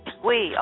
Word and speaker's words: Quy: Is it Quy? – Yes, in Vietnamese it's Quy. – Quy, okay. In English we pronounce the Q, but you pronounce it like a W.